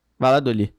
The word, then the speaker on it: Valladolid: fala Valor ID? Não é como é que fala? – Não é Valladolid, Valladolid? não? – Valladolid.